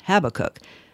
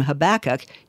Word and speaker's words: Habakkuk: Okay, actually his name should probably be pronounced Habakkuk, but since I grew up calling him Habakkuk, you're stuck with my pronunciation.